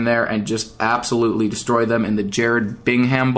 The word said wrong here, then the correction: Birmingham: Bowl. Birmingham Bowl. Sorry for pronouncing that the wrong way.